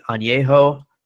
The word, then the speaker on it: añejo: You would think I would be more of a fan of the age or the añejo. Tell me if I said that right, Jeff. – Yep.